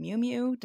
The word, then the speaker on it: Miu Miu: Miu Miu. Did I say that right, Laura, my fashion friend? – Yep, yep. Good. – Great. – Miu Miu.